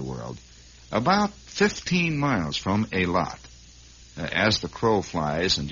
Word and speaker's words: Eilat: is a tiny settlement called Eilat. E-I-L-A-T. Or Eilat, if you prefer it that way. Generally pronounced by the people in that area as Eilat.